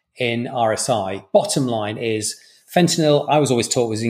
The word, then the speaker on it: fentanyl: Paper six, fentanyl for rapid sequence induction, the FACT study. Did I say that right? – Yeah, you did, yeah.